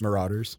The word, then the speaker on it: Marauders: Am I, am I, I hope I'm pronouncing that word right. First Marauders. Marauders. There we go.